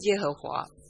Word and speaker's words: 耶和华: 后来我们所熟悉的耶和华这个名字，也正是源于这段经文。希伯来语中耶和华这个名字就出自这段经文，我们发音为耶和华。